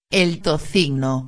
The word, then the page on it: el tocino: espanhol